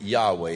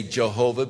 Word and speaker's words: Yahweh: We're not certain of the pronunciation, but most scholars believe that Yahweh is probably the correct pronunciation. We are prone to say Jehovah because it starts with a J in its transliteration into English, but there is no really J sound in the Hebrew language,